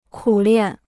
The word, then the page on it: 苦练: kǔ liàn